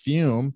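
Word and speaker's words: FÜM: Fume, pronounced Fume, but spelled F-U-M, is the natural inhaler designed for a better, safer, and natural way to quit smoking cigarettes.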